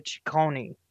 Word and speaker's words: Ciccone: Madonna Louise Veronica Ciccone.